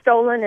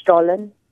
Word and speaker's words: Stollen: The is it Stollen? Is that how you pronounce that? – What's Stollen is how you say it here. It's over in Germany, it's pronounced Stollen.